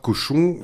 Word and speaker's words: Cauchon: Alors, pour ceux et celles qui, comme moi, sont des quiches en histoire, Cochon, ça s'écrit C-A-U-C-H-O-N.